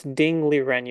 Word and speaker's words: Ding Liren: Ling Duren, how do you spell it? – No, it's Ding Liren. You have it backwards.